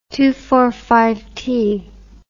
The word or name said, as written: two for one